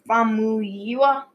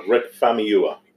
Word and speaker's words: Famuyiwa: However, that didn't happen because it lost two directors, Dopes Rick Famuyiwa. – Yep. – Famuyiwa. Yeah. Forgive me if we said that wrong. – Yeah, Rick Famuyiwa.